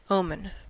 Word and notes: omen: American English

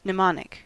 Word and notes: mnemonic: American English

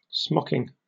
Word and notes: smocking: British English

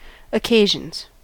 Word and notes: occasions: American English

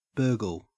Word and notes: burgle: Australian English

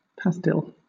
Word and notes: pastille: British English

